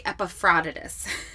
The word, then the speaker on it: Epaphroditus: And then I heard another, just recently through another podcast, heard another way of pronouncing his name. And I always have to work it out in my brain. That scholar was pronouncing his name Epaphroditus. Okay, Epaphroditus. So we're going to say Epaphroditus.